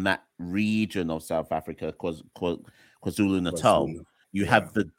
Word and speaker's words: KwaZulu-Natal: You were in, I always say it wrong, but let's just say Natal. But I always get the name wrong. – KwaZulu-Natal. – Thank you, KwaZulu-Natal.